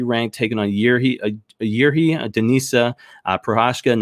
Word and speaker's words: Prochazka: Reyes versus, and I've got his name, Prochazka is how you pronounce it. It's Prochazka. But I went and figured out how to pronounce it earlier. It's Prochazka.